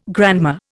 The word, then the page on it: grandma: Indian